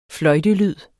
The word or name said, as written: fløjtelyd